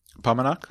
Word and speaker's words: Paumanok: So pop quiz across Palmer and Pomonok. – Thank you. My brain I'm not going to say it right.